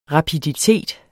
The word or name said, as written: rapiditet